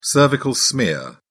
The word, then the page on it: cervical smear: British English